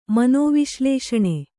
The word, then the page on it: ಮನೋವಿಶ್ಲೇಷಣೆ: manōviślēṣaṇe